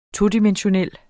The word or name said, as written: TODIMENSIONALE